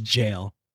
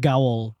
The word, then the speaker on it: gaol: Spelled G-A-O-L. But I looked it up. It's pronounced jail. – Yeah. We don't know how to spell up here. – My dumbass said gowl.